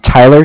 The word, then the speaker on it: Tyler: Okay, my name is Tyler, T-Y-L-E-R. And I'm calling from Bank of Cardiff. It's spelled C-A-R-D-I-F-F, as in Frank Frank.